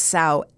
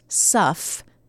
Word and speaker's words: sough: Old Sow gets its name not from the sound that it makes, most likely from the word sow, S-O-U-G-H, okay? Pronounced suff.